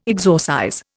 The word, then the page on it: exorcize: Indian